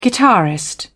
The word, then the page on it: guitarist: British English